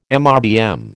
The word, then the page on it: MRBM: American English